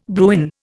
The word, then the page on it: Bruin: Indian